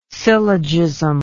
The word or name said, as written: syllogism